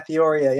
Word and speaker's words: Theoria: Yeah, Theoria.